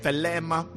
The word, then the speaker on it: thelema: thelema, which is spelled T-H-E-L-E-M-A. Thelema. It almost sounds like Thelma. Alright? Thelema.